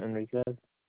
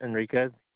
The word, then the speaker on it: Enriquez: Brent Enriquez. – I'm sorry. Can you repeat that, sir? I'm sorry. – Brent Enriquez. – Can you spell that for me? – Brent is B-R-E-N-T. Enriquez is E-N-R-I-Q-U-E-Z. – And you say your last name was E-N-R-I-Q-U-Z or E-Z? – Q-U-E-Z, yeah.